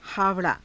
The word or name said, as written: haava_raa